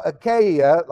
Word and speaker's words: Achaia: Okay, now Achaia, like I said earlier, that's the correct pronunciation now, is the Roman province in southern Greece.